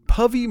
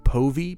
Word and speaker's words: Povey: Povey might be pronounced Povey, P-O-V-E-Y. I might stick with Povey. That sounds a little better.